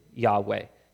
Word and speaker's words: Yahweh: But most scholars now believe the original pronunciation was Yahweh, and that's why I'll be using that.